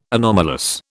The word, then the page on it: anomalous: British English